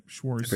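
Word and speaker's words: FAO Schwarz: Once filming wrapped, they were sold to FAO Schwartz. I pronounced that right. Schwartz.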